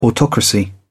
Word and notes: autocracy: British English

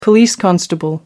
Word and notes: police constable: American English

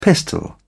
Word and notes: pistol: British English

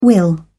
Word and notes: will: British English

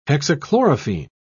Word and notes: hexachlorophene: British English

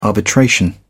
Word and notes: arbitration: British English

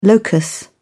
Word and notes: locus: British English

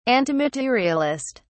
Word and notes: antimaterialist: British English

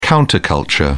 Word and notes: counter culture: British English

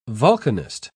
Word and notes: volcanist: British English

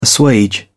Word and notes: assuage: British English

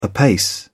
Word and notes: apace: British English

